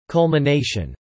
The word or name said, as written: culmination